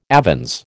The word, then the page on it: avens: American English